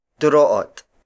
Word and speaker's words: Duroot: Duroot. Duroot. Duroot is spelled as D-U-R-O-O-T.